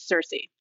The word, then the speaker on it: Circe: So there's the modern day English pronunciation, which is what I went with for the book, which is Circe. But the original Greek is CIRCE. – Oh. – So it's the hard C sound.